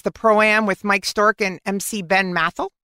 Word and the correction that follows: Mathai: Did I say that right? – Ben Mathai.